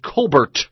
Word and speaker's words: Colbert: Stephen Colbert's sister, as I said at the top of the show, his sister, Elizabeth Colbert Bush, really how you pronounce her name is Colbert, is running as a Democrat in South Carolina.